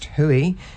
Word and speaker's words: Hui: Hui, pronounced Hui, devised by Hinerangi Marie Berryman and Benny Marama, June 29th until July the 1st.